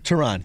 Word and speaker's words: Teheran: They got Julio Teheran. That's how you say his name, right? – Yeah, Julio Teheran. – Teheran, yes. And there was one other guy who I'm trying to think of.